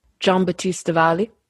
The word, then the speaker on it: Giambattista Valli: Giambattista Valli.